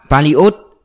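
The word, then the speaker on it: Paliot (полёт): Phải gọi là Paliot, trọng âm ở Iot, nghĩa là chuyến bay.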